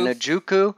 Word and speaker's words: Njoku: Okay, I'm actually liking him in some stacks with that Njoku. What's that tight end? You can pronounce. Yeah, Njoku.